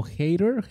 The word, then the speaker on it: Hader: Su apellido es, o sea, se pronuncia como Hater, pero con D, como Hater.